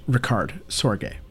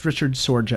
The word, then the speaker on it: Richard Sorge: His name is pronounced Ricard Sorge. I thought it was Richard Sorge at first, but he was neither British nor American.